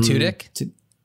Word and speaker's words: Tudyk: And I thought about Alan, I don't know how you pronounce his last name, Tudyk? – Alan Tudyk? – Alan Tudyk, thank you.